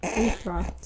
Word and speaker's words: Aethra: So, Aegeus told Aretha... Not Aretha. Aethra. – Sorry, it's spelled with an A. – Aethra? – It's pronounced Aethra.